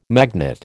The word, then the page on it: magnet: American English